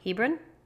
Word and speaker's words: Hebron: Hebron? – Yes, but it's like these trees of Mamre.